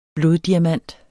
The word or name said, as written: bloddiamant